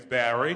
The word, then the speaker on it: Barry: And I said, "My name is Barry." And she said, "Spell it." I said, B-A-R-R-Y.